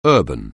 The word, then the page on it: urban: anglais